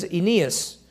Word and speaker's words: Aeneas: And there he found a certain man named, and the way you pronounce that is Aeneas.